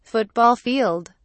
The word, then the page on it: football field: British English